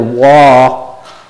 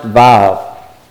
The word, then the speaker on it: vav: It is the section where you have this Hebrew letter, waw, pronounced vav. Okay, it's pronounced vav.